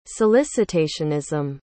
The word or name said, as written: solicitationism